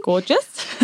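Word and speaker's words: Gorjuše: There was another place we went in a village called, I'm going to have to spell it, G-O-R-J-U-S-E. Gorgeous, maybe? – Gorgeous. – Gorgeous, yeah.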